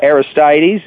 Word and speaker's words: Aristides: So the Aristides is how we're going to pronounce it, because that's the way our announcer did.